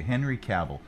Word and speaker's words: Henry Cavill: Okay, Henry Cavill. Let's get some music in here. I know we're running long, but here, let's get some music. So Henry Cavill. – Henry Cavill.